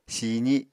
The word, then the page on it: siizi: Apatani